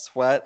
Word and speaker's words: Sweat: You know, my last name gets pronounced many things, and if it's anything besides Sweat, I usually don't correct people because anything sounds better than that.